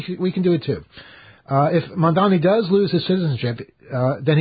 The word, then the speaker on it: Mamdani: If Mamdani does lose, see, I said it wrong there, too. Sometimes I get it wrong. I said Mamdani. Mamdani. We can all practice together. – Mamdani.